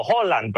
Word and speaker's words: Haaland: sobre algo que dijo Haaland, la estrella de Noruega. – Haaland. – ¿Okay? O Holland, perdón, se me olvida que esa es la pronunciación correcta, Holland.